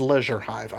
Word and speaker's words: Leisure Hive: There are several good things about The Leisure Hive and the stories that follow, and yes, it is Leisure Hive. I think that's the British pronunciation, though I've heard British people say leisure, but Leisure Hive is the way they pronounce it always.